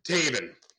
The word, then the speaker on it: Taven: Now, your son's name, am I saying that right? Tavin? – Taven. – Taven. – Like Raven, okay. But with a T. Taven.